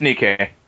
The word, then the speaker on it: Nikkei: Nikkei. That's the one that we're talking about. Okay. – Yeah, I don't have a clue how to pronounce it, but yeah. – It's Nikkei.